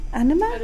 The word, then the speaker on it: anime: Various forms of Japanese art, from woodblock prints to manga and anime? – Anime.